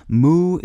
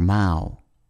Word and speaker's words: mow: Moue is one of two similar sounding words in English that refer to a pout or grimace. The other is spelled M O W and pronounced mo or mao.